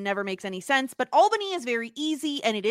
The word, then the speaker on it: Albany: And of course, as always, I'm joined by someone who definitely knows how to pronounce Albany, Stuart. Stuart, how are you? – Albany. – Yes, thank you. – Albany.